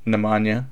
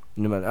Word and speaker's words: Nemanja: How do you say his first name? Nemanja. – You got that right. Nemanja.